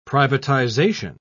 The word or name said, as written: privatization